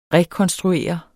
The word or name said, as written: rekonstruere